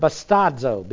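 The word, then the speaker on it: bastadzo (βαστάζω): Now, this is the word bastadzo, B-A-S-T-A-D-Z, long O.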